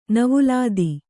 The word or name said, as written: ನೌವಾಲಾದಿ